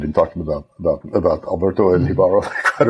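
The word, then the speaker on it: Jíbaro: I think it's pronounced Jibaro. Jibaro. Yeah, because he's Spanish, right?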